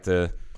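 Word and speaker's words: açaí: Acai. – Yeah. Yeah, it's the soft C. It's it's Portuguese. Yeah.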